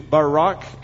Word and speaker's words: Barak: We're also introduced to Barak, and I know that that is the Hebrew way to pronounce it.